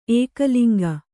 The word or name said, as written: ಏಕಲಿಂಗಿ